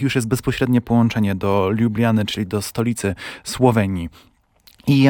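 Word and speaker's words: Ljubljana: Czy to z Lublany do Klagenfurtu, czy z Klagenfurtu do Lublany, w zależności do Lubliany właśnie, bo ja tutaj też naciskam na tę słoweńską wymowę ze swojej strony.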